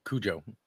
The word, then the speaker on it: Cujo: Did I say that right? – Cujo. Yeah. – Cujo. Yeah. I mean, it depends which accent.